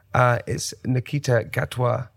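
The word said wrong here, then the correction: Ncuti: Is it Nakuti? Nakuti.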